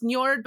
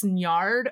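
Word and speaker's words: Njörd: I've also heard it pronounced Njord, but apparently with the two dots over the O, it's Njard, according to some like, you know, some native speakers that I heard from.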